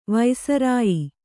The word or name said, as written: ವೈಸರಾಯಿ